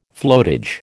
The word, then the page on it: floatage: American English